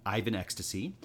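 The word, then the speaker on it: Ivans xtc: The failure of his 1997 adaptation of Anna Karenina inspired him to give up mainstream filmmaking and move to independent films like Ivan XTS, pronounced Ivan Ecstasy, and The I like that you said it wrong and then told us how to say it right.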